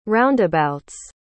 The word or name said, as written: roundabouts